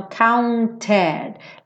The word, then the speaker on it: counted: Stand up and be counted. Aqui a gente pronuncia esse E do counted, porque a gente tá vindo de um T. Né? Então, counted.